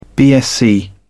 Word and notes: bsc: British English

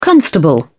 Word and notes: constable: British English

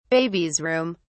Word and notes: baby's room: British English

